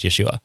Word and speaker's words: Yeshua: Yeah, it more most likely would have been pronounced Yeshua.